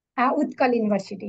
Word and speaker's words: Utkal University: Utkal University, yes. – Okay. I looked it up and I was like, wow, this is like a very very historic type of thing here. – Utkal University in Odisha.